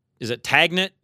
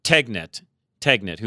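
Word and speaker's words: Tegna: I will say that Tagnet, am I saying that right? Tegnet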